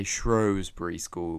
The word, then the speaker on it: Shrewsbury: It is called Shrewsbury School, or Shrewsbury School, depending how you pronounce it. Most people say Shrewsbury School because this is a more posh, more proper pronunciation.